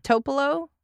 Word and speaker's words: Tupelo: Tupelo. – T-U-P-E-L-O. Birthplace of Elvis, baby. How do you not know that? Oh. – So one that we've watched is Kings of Tupelo.